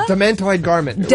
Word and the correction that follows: garnet: Demantoid, not a garment. A garnet.